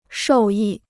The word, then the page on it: 受益: shòu yì